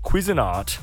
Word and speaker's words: Cuisinart: We have a Cuisinart.